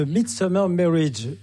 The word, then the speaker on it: The Midsummer Marriage: C'est The Midsummer Marriage.